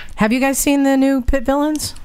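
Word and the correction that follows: Vitpilen: I think it's a Vitpillin. – Vitpillin? Am I saying it wrong? – Yeah, it's Vitpillin. – Vitpillin, yes.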